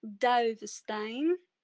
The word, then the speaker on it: Dovestein: My name is Melissa Dovestein. – Is that how you... Okay, my screen reader was totally getting your last name wrong. Okay. – Yes. You get that with these Dutch last names.